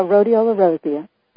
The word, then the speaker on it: rhodiola rosea: And it makes mention that the rhodiola rosea, is that pronounced right? – Yeah, rhodiola rosea.